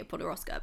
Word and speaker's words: Podoroska: Nadia Podoroska, but am I saying her name right? – Yeah.